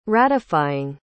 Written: ratifying

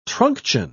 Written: truncheon

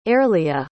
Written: airlia

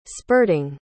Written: spurting

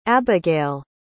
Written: Abbigail

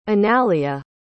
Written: analia

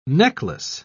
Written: necklace

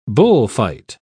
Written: bullfight

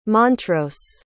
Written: montrose

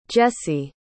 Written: Jessy

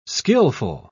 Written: skillful

